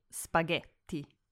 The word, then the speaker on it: Spaghetti: So when you see GH together, it's always a hard G, just like in spaghetti.